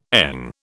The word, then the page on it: ENG: British English